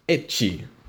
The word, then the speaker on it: ecchi: But they pronounced it ecchi, for the letter H.